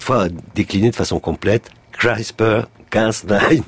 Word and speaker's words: CRISPR-Cas9: avec l'accent ou la prononciation anglaise, puisque de toute façon c'est l'acronyme d'un mot anglais, c'est CRISPR-Cas9,